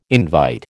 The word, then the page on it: invite: American English